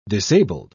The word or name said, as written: disabled